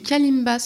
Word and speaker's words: kalimba: Et tu joues aussi du kalimba, si je le prononce bien. – Oui.